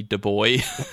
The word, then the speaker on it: Du Bois: Du Bois. – Oh, you idiot. You pronounce the S.